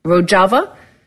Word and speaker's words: Rojava: Rojava, where he not only fought ISIS but talked some economic ideas to the people of Rojava, or Rojava, as I say. I pronounce it the Spanish way sometimes.